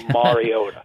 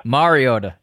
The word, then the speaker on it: Mariota: Mariota. – Mariota, yeah.